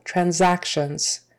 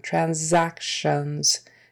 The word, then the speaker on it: transactions: The syllable stress is the second syllable, transactions. And that S in the middle is a Z sound, transactions. T I O N, that T I O is it's an SH sound.